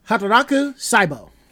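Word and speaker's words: Hataraku Saibō: Hataraku Saibo.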